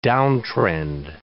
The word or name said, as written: downtrend